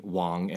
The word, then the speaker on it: Wong: In seventh grade, I didn't understand the difference between Wong and Wang, like W-O-N-G, W-A-N-G. I just didn't know that. So I always was wondering, wait, why is our last name Wang when you pronounce it Wong? I was like, okay, in my screen name, I'm going to make it phonetically correct, not pinyin correct or whatever. So I'm going to make it Wong.